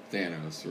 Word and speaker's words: Thanos: No, I was being Thanos or whatever.